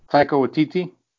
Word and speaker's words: Taika Waititi: Taika Waititi? – Are you confident? – That's it. Yeah, I would say.